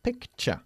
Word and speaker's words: picture: All words that end in T-U-R-E are pronounced ch, okay? Not ture, ni tur. It's ch, like picture.